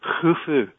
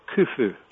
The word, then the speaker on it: Khufu: It's actually pronounced as in loch. So it's actually Khufu as opposed to Khufu. But most people just say Khufu.